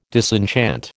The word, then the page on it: disenchant: American English